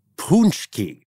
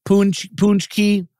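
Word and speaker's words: pączki: Because it is apparently pawn check, punch key.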